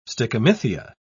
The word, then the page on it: stichomythia: British English